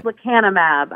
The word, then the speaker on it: lecanemab: So it's lecanemab? Lecanemab? – It is lecanemab. I don't know why we can't name drugs more easily. I'm with you, but lecanemab is the newest to report out.